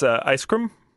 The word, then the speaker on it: Ice Cream: Sure, it's Ice Cream. – How do you spell that? – I-C-E-C-R-E-A-M.